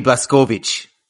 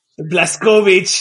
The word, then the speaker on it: Blazkowicz: Or as we say, if you want to correctly pronounce it, BJ Blazkowicz. – Blazkowicz, there you go.